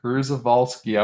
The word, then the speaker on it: Przewalski: I have the pronunciation. It's Przewalski. – Przewalski.